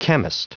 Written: chemist

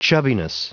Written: chubbiness

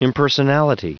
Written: impersonality

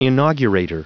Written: inaugurator

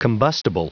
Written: combustible

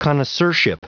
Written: connoisseurship

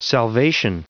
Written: salvation

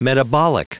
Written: metabolic

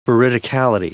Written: veridicality

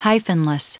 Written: hyphenless

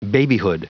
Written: babyhood

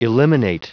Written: eliminate

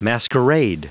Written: masquerade

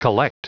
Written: collect

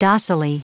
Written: docilely

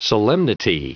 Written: solemnity

